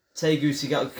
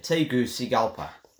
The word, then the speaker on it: Tegucigalpa: Tegucigalpa, because it's split up, pronounced here. Tegucigalpa.